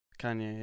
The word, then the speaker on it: Kanye: that Kanye? – Kanye, yeah.